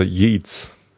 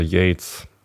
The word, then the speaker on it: Yeats: Oder Yates. – Uh -uh. – Ich glaube eigentlich Yates, weil ähm, äh, Y-E-A-T-S äh, würde man wahrscheinlich eher Yeats aussprechen, wenn man äh, wenn man es nicht kennt.